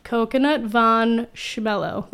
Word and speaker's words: Coconut Von Schmello: Coconut Von Schmello. The Von is very important, you know. – Yeah. – Really emphasis on the Von.